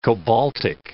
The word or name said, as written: cobaltic